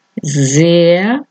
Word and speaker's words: sehr: La H en el medio no se dice, no se pronuncia, alarga la vocal anterior. Sehr, Stuhl, Meer, Sohn, Uhr, Lehrer.